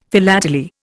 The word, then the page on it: philately: Indian